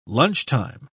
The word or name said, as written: lunchtime